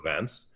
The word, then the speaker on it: Reims: Reims is spelled Reims, but pronounced Reims.